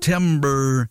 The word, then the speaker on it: Timbre: It's called Timber, but it's spelled T-I-M-B-R-E. Timber.